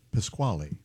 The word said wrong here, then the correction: Pachelbel: By Pachelbel. – You pronounce it Pasquale? – No.